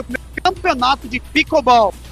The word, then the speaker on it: pickleball: Campeonato de pickleball. – Ficou meio travante essa sonora. Vamos tentar de novo. 17 horas e 44 minutos. É, rapaz, teve aí o plic. Pickleball, é assim que fala, ó.